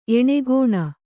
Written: ಏಣಗೋಣ

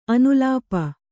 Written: ಅನುಲೇಪ

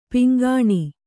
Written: ಪಿಂಗಾಣಿ

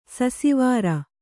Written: ಸುಸ್ವರ

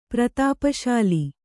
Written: ಪ್ರತಾಪಶಾಲಿ

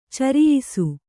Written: ಚೌರಾಯಿಸಿ

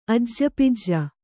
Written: ಅಜ್ಜಪಜ್ಜ

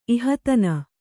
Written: ಇಹತನ

